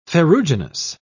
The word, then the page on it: ferruginous: British English